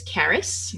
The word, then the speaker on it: Caris: Her name is Caris, which rhymes with Paris, and it's a Welsh name, and she is 4 years old.